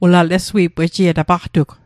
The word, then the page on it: Lesui'p: Mi'gmaq